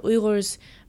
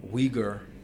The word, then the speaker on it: Uyghur: Yeah, so it's like there's like a ra sound. It's like a G, it's spelled U Y G H U R. So the G H makes a ra noise.